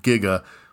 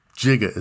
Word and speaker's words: giga: Just about everyone is familiar with kilo, mega, and giga, or jiga as some people say. Pronouncing it that way is technically acceptable, but not in my house.